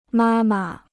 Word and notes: 妈妈: mā ma

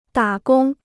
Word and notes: 打工: dǎ gōng

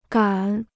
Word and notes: 敢: gǎn

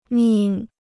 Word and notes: 命: mìng

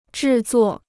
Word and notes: 制作: zhì zuò

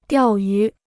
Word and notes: 钓鱼: diào yú

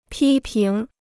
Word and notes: 批评: pī píng

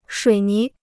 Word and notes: 水泥: shuǐ ní

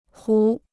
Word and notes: 壶: hú

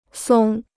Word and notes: 松: sōng

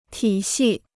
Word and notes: 体系: tǐ xì

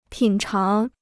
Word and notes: 品尝: pǐn cháng

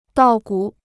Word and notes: 稻谷: dào gǔ

